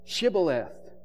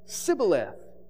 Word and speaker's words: Shibboleth: And then, verse 6 says, they would say to them, say Shibboleth. But he said, Sibboleth, for he could not pronounce it correctly.